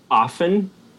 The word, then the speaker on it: often: He says often incorrectly. He pronounces it with a T.